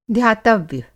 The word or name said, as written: ध्यातव्य